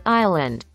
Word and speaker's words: island: Ustedes no lo van a escuchar, pero escuche gente, así se pronuncia en inglés. A ver. – Island. – Ajá, se dice sin ese.